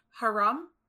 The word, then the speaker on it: haram: Forbidden or illicit in Arabic is actually, I believe it's haram. – Oh, that's how you say it.